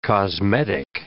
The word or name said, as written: cosmetic